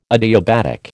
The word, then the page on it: adiabatic: American English